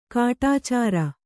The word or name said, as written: ಕಡೆಚೋರಿ